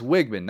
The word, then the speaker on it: Weigman: And it's Weigman, not Weigman. We say Weigman because it's the grocery store. It's Weigman. We got to say it's Weigman. He spells his first name wrong, but his last name, that's how we got to pronounce it.